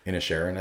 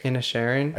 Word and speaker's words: Inisherin: I probably butchered that. – Inisherin, I think. – Inisherin.